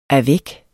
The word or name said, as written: avec